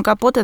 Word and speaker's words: Капоте: Ой, а чей, собственно, он у нас капоты, он же вообще, наверное, капоты, так мне мне кажется. – Он Капоте, да, я, мы как-то что-то напутали. Я просто помню, что есть какие-то два варианта произношения его фамилии по-английски, поэтому, конечно, простите, он э, Труман Капот.